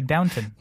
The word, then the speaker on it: Downton: Damn, I always thought it was Downtown Abbey. – Well, yeah, and it's funny, I did too, because the way they pronounce it, I was like, oh, I just thought it was just some weird British way of pronouncing shit. But no, it's actually Downton.